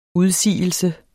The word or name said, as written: udsigelse